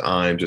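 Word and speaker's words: Ijams: go over into Imes. Uh, it's it's pronounced Imes. It's spelled I Jams but it's a beautiful nature area out there.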